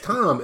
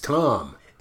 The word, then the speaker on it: Thom: No, it's Tom. And for some reason, Thom is Tom.